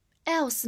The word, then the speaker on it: else: 下一个单词，else，e l s e，else，第一个字母 e 发最常见的小口 e，所以 e l l，然后 s 发 s，末尾的 e 不发音，else。 else 呢，它是一个形容词，也是一个副词，表示其他的、别的。